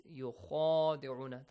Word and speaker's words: يُخَادِعُونَ: So, the first important point on Tajweed rules is يُخَادِعُونَ, the letter خَ. It has an alif, yes? Alif mad. It's pronounced the same as the name of this letter. خَ. Alif, ba, ta, tha, jim, ha. خَ. Okay, heavy letter. There is an alif, you gotta stretch it for two counts.